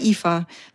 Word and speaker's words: Aoife: Mich fragt man in Irland immer, wie Eva mit IWE oder, weil es gibt ein gälisches IFA und das schreibt man mit A-O-I-F-E. – Na, A-O-I-F-E. Und das sagt man aber IFA, weil alles bis zum I bleibt stumm.